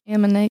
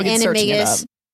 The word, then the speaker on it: Animagus: You can't teach people to be an Animagus, right? – Animagus. – I just butchered that term. – Animagus.